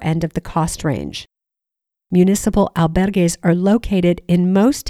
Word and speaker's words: municipal: And by the way, in Spanish, it's pronounced municipal. Next, parish or parochial, which is pronounced parochial, albergues are run by the local church or diocese.